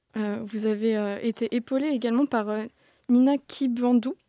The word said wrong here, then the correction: Kibanda: Je crois que je le prononce. – Kibanda, merci.